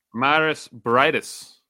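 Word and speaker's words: Mairis Briedis: Is it, how do you pronounce Marius? – Maris Bradis.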